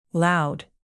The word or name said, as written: loud